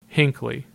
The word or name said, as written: Hinckley